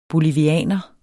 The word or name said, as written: BOLIVIANERE